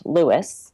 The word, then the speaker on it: Lewes: So the northernmost one is Lewis, which is spelled L-E-W-E-S, but is pronounced Lewis, not Lewes.